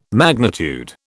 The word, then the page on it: magnitude: British English